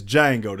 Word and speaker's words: Django: That's almost like having the Django 2. – It's Django. The D is silent, nigga.